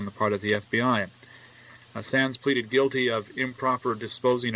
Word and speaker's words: Sannes: District Court order was based on charges brought yesterday by David Sanes, I guess is the way it's pronounced, S-A-N-N-E-S, charges against Stan Pitkin, U.S.